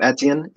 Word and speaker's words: Etienne: And let's see, projected starting lineup is Trevor Lawrence, Travis, how do you say his name? – Etienne.